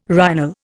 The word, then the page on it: rhinal: Indian